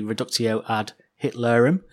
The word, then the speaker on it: reductio ad Hitlerum: Well, let's hear Tom using the reductio ad Hitlerum. Is that how you say it? – Yeah, that's right.